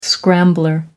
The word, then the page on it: scrambler: American English